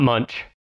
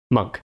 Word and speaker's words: Munch: Not Munch, Monk.